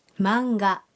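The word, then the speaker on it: マンガ: هذه القصص المرسومة تنطق هكذا. – مانجا.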